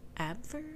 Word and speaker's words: Abwehr: A-B-W-E-H-R? Abwehr? – Abwehr? – Abwehr? – Okay. Is it a German word? Sense. – Yes. – Then the W should be pronounced with the V, I think. – A V, Abwehr.